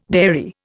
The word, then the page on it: dairy: Indian